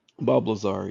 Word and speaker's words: Bob Lazar: If anyone wants to look up Bob Lazar, am I saying that right? Bob Lazar? – Bob Lazar, yeah.